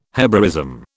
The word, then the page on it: Hebraism: British English